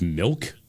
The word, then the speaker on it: Milck: And then somebody named I, I guess it's pronounced Milk. Milck.